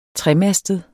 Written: tremastet

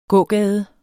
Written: gågade

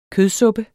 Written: kødsuppe

